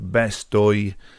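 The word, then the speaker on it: bestoj: Oi rhymes with toy, so we have bestoi, catoi, and so on.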